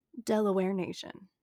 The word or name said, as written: Delaware Nation